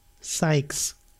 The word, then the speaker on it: Sykes: Oh, ich lese gerade, der Held von Last Remnant hieß Rush Sykes. – Wie schreibt man den Nachnamen? S-Y-K-E-S. S-Y-K-E-S. E wow. Sykes.